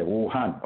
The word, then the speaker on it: Wuhan: So Wuhan is spelled W U, an umbrella, and then H A N. – Okay, Wuhan.